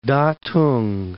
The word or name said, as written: Tatung